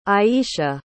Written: aisha

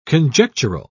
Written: conjectural

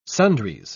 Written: sundries

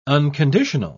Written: unconditional

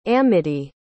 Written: amedee

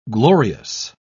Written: glorious

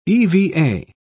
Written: eva

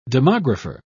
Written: demographer

